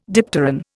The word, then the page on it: dipteran: Indian